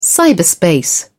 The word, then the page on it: cyberspace: British English